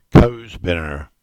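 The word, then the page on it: Binner Wood: Cornish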